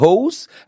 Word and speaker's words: Hause: c'est Dave Hose. Alors c'est Dave, D-A-V-E bien sûr, et Hose, ça s'écrit H-A-U-S-E.